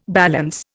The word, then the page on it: balance: Indian